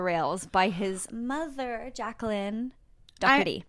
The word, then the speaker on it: Doherty: Or I know that I think if you're from London or from England, it's pronounced Doherty. Pete Doherty. So Pete Doherty.